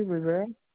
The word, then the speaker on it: Rivera: Nancy Rivera.